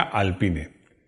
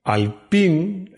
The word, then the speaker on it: Alpine: Alpine, en francés se pronuncia alpine, significa alpino. Y no se dice alpine en inglés.